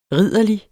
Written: ridderlig

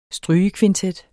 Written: strygerkvintet

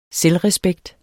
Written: selvrespekt

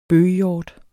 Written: bøghjort

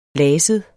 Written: laset

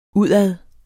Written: udad

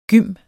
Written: gym

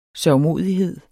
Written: sørgmodighed

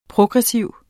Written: progressiv

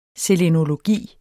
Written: selenologi

